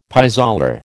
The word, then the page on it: pis aller: American English